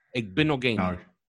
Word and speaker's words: Igbinoghene: Noah Igbinoghene. – Igbinoghene.